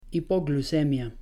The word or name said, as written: hipoglucemia